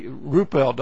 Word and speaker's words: Ruppelt: Did that change Rupert's mind? – No, he didn't. It did not. – Okay, Ruppelt, I guess, is the actual way he pronounces his name.